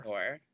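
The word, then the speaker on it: Oher: Well, retired NFL star Michael Orr, right? That's how you say it? Orr. – Orr.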